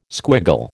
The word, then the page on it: squiggle: American English